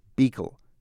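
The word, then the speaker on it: Büchel: The missionary whose work left the greatest impression on Marie was a man called Eugene Beekel. That's the way they pronounce it here. His last name is spelt B-U-Umlaut-C-H-E-L.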